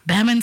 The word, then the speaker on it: Bamman: And when I saw it, honestly, all I thought was to train my brain to, you know, to pronounce it, I was thinking Bamman sounds like it could be closely pronounced like gammon.